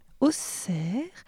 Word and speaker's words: Auxerre: on doit prononcer Auxerre, Bruxelles, puisqu'il s'écrivait en néerlandais Bruxelles avec deux S, E et L.